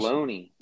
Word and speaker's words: tortelloni: It's spelled with an O, like ONI. – Oh, then that must be some difference. – Tortelloni.